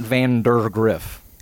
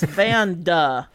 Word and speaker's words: Vandergriff: Yeah, because it's not Vandergriff. – Vandergriff. – It's Vanda, like Panda Griff. – Vandergriff. – Rhymes with Panda Grift.